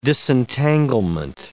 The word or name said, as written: disentanglement